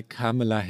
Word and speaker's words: Kamala: dass bei, äh, bei Kamala Harris der, die zweite Silbe betont werde. Und das war ja total falsch.